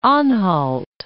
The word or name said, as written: Anhalt